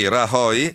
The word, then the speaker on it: Rajoy: Domani il voto di investitura del nuovo premier Mariano Rajoy, spero che si pronunci così, no, non si pronuncia così, mi dicono dalla regia, adesso Rajoy, scusate, e però il piano che ha annunciato vale 16 miliardi e mezzo di tagli dove e soprattutto come?